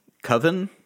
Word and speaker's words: coven: And for that, for this particular spell, we need a whole covenant. Is that how it's pronounced? Convent? – Convent is like where nuns live. – No. – Coven? Is that what you're looking for? Coven of witches? – Yeah, coven of witches.